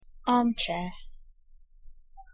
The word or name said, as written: armchair